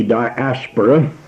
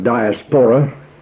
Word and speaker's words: diaspora: And the 5.5 million, we can call them the exiles, but Scripture would call them the diaspora, and that is spelled D-I-A-S-P-O-R-A. And when it's carried over into English, it is commonly pronounced diaspora, but I believe that the pronunciation of the Greek word would put the accent on the second syllable, and we would say diaspora if it's the Greek word, diaspora if we're trying to make it more of an English word.